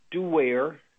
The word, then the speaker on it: Duerre: My name is Ralph Duerre. That's spelled D-U-E-R-R-E, and I'm an assistant municipal attorney with the Municipality of Anchorage.